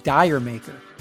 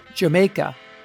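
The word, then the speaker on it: D'yer Mak'er: Quote, I remember many DJs saying Dire Maker. The whole band used to get a tremendous laugh out of hearing American DJs trying to pronounce something which is a play on words. It's Jamaica. Did you make her? That's what it means.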